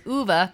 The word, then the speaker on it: Ove: And that's how I learned that it was Uwe, because it's spelled O-V-E. And as an American, we're like, Ove? What? Yeah. How do you say that? But it's Uwe.